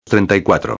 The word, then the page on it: 34: espanhol